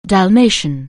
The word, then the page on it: Dalmatian: anglais